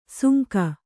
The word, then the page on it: ಸುಂಕ: sunka